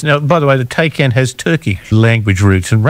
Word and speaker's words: Taycan: I might have said a Tay, but it's not that, is it? – No, it's a Taycan. – T-A-Y-N.